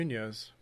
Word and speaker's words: Munhoz: Munhoz, I think it's how you say it. – Yeah, Munhoz. Yeah.